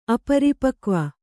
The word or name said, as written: ಅಪರಿಪಕ್ವ